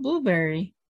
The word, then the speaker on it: Blubrry: And that's spelled Blubrry. It's Blueberry without the E's.